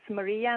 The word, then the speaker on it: Marija: So it's easy to find me on Facebook or on Skype, and my name is Maria, and it's spelled M-A-R-I-J-A, and my last name is Strat, S-T-R-A-T.